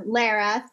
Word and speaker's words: Lara: You got my first name is like Lara, Sarah with an L.